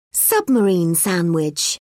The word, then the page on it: submarine sandwich: British English